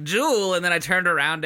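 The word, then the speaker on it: Juel: It's J-U-E-L. So I said Jewel.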